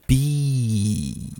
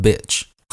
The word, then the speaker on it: beach: 但是这个字呢，海滩、沙滩呢是 beach，可以吗？不要念成短音，就会变成 bitch，就会变成什么没有被污染的 bitch，好，就不能可以讲，不可以这样子。